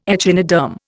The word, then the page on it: echinoderm: Indian